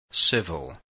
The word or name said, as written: civil